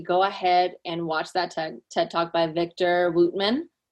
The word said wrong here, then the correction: Victor Wooten: Victor Wooten. – Yeah, Victor Wooten. Yeah, how do you say his name?